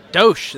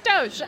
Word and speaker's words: DOSH: And am I going to pronounce it right? Let's see. DOSH? – DOSH. That's right. – I got it. Not DOSH. It's DOSH.